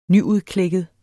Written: nyudklækket

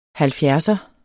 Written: halvfjerdser

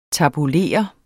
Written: tabulere

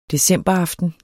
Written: decemberaften

